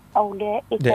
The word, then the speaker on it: أولئك: أولئك. – مش هذيك الواو ما تنطقش.